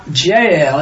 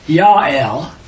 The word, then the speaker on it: Yael: Her name is not Jael, it's really Yael, because it's J replaced with a Y.